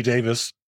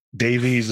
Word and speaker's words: Davies: I mean, and Ray Davis of the Kinks. – Right. – That's actually how you pronounce it, but Davies is what we call him here.